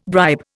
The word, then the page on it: bribe: Indian